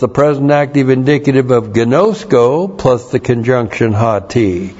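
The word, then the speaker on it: gnosko (γινώσκω): And then, gnosko. G-I-N long O-S-K long O. Gnosko.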